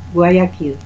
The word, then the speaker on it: Guayaquil: Ja, Guayaquil.